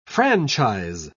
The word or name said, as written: franchise